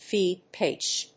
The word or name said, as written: feed pitch